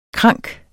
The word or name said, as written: krank